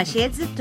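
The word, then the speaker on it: 鞋子: うん、実はシェーズというのは、悪いという意味の同じ発音で、うん、で靴という中国語はシェーズというから悪いとか不健康とかそれ同じ意味なので、春節中は靴を買わないということになってます。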